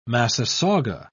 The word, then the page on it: massasauga: British English